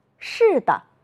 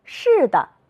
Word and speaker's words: 是的: 扇子，是的，耷拉，竖着。你发现了什么？有的同学说，老师，后一个字都要读成轻声。你们真会观察，我们再来读一读。扇子，是的，耷拉，竖着。